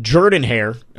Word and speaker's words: Jordan-Hare: This week you get Auburn in, we've figured out Jordan-Hare, apparently is how it's supposed to be pronounced.